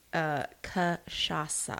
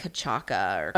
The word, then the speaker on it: cachaça: But anyway, it is cachaça. Mm-hmm. And I had to look up how to say it because I was saying... – Kasasha. – No, I was saying like Kachaka or, you know, like a stupid white girl. I was saying it totally wrong.